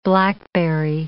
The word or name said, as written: blackberry